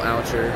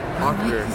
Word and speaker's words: Auchter: We're here at the 82nd annual meeting for the Society of American Archaeology in Vancouver, British Columbia, with Bill Auchter. Did I say your last name right? – Well, Auchter. – Auchter, sorry. Butchered that.